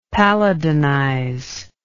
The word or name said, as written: palladinize